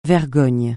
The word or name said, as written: vergogne